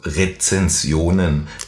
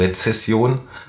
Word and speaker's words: Rezension: Und von den Sternen und Social Media möchte ich jetzt die Verknüpfung machen zu Rezensionen, die die meiste übrigens auch falsch ausspreche, weil ich habe es auch immer falsch ausgesprochen, Rezession, mhm. aber das ist wieder was anderes, das ist das, was jetzt wirtschaftlich kommen mag. Mhm. Wir sprechen von einer Rezension.